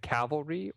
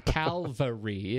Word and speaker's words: Calvary: I, I think, is it Cavalry or Calvary? – Ca- oh God, this is like nuclear, nuclear, nuclear. well, I can't say that wrong. Calvary. It is Calvary. I'm a dope. That'll get me in trouble. I'm getting email on that. – Calvary. – Thank you, because it's not cavalry.